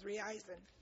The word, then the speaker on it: Reizen: Okay, the thermometer that we were testing in my hand is called a YL101 Talking Thermometer by I pronounce it as Reizen. And that's sold through Maxi Aids. R-E-I-Z-E-N.